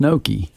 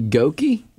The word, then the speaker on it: gnocchi: Well, it's spelled G N O C C H I, and so I just mispronounce it how I want. Yeah, wouldn't that be goki? Nope, because it's a gnocchi. You got the G.